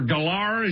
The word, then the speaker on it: Gellar: Remember Sarah Michelle Gellar, or Gellar, as she likes to tell everybody, is how you pronounce her last name? – I can't get over that.